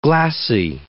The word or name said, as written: glassy